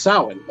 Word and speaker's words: Samhain: Samhain. – Okay, so that you do pronounce it. Okay. – Yeah. – It's pronounced Samhain, but I didn't want to butcher it.